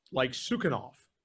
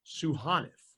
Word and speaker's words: Sukhanov: His last name looked like Sukhanov, and everybody was pronouncing it that way. And his host family came to me and said, it's pronounced Suhanov.